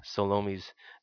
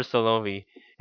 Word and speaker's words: Salome: Now, I'm not butchering Salome's name. We call her Salome here in church, but according to scripture, the way that they pronounce that name was Salome.